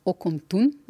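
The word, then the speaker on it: Ocomtún: Eh, veliko odkritij, največ pozornosti svetovne javnosti pa je vzbudila nedavna najdba, mesto Okomtun. Rekli ste, da sem pravilno izgovorila.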